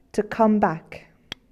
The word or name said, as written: come back